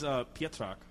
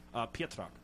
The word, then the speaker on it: Pietrak: It is Pietrak. It's sort of like the Z is silent. So it's one of those Polish stuff. – We both let's hear it one more time. – Pietrak.